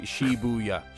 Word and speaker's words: Shibuya: Actually, they would say Shibuya. – Oh, yeah. – Yeah, yeah. So okay. It's just three syllables.